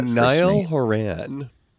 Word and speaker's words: Niall Horan: Niall Horan. – Niall Horan.